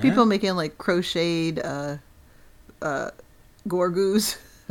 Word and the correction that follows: Grogu: or however you say his name. Grogu. – Grogu. Okay.